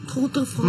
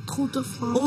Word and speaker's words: Tour de France: Tour de France. Mañana termina. – Ay, ¿cómo se dice? – Tour de France.